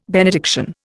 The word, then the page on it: benediction: Indian